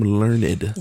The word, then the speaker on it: learned: I am learned. – You are learned. – Learned, mm-hmm, in the top. – Two syllables. Two whole syllables.